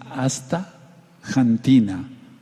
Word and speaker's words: astaxantina: y si quieren se las deletreo: A-S-T-A, ahí dice hasta, pero sigue la palabra corrida, X-A-N-T-I-N-A, astaxantina. Se podría pronunciar como si dijéramos México o México, pero lo más correcto es decir astaxantina.